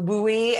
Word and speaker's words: WUI: It is the WUI, as I learned to say when I did the audiobook, because I didn't know how to say it, which is people desiring to live in the forest.